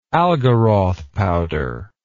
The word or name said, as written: Algaroth powder